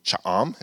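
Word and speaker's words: Cha-am: Last time we spoke, we were planting a church in Cha'am, is how it's pronounced.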